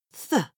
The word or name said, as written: th